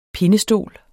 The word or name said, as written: pindestol